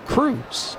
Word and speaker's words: Croes: Alan Cruz spells his last name C-R-O-E-S. But it is pronounced Cruz. And I actually have it spelled in my book, C-R-U-Z. Just to be sure we get it right.